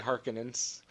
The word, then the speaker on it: Harkonnens: I think they saw that there would be like a war with the Harkonians if they couldn't bring peace through marriage. – I gotta correct you. Just say Harkonnens.